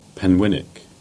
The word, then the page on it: Penwinnick: Cornish